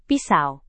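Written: pisau cukur

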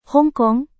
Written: hong kong

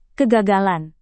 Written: kegagalan

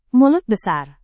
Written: mulut besar

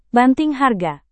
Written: banting harga

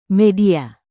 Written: media

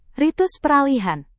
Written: ritus peralihan

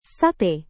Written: sate

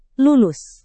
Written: lulus

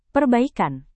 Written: perbaikan

